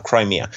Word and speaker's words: Crimea: Yeah, Crimea. Yeah, they have a big base in Crimea. So in from military perspective, it does make sense that they would annex the Crimea peninsula.